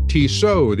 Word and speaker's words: Tissot: The Steam Room is brought to you by Tissot, the official watch of the NBA. The last T is silent.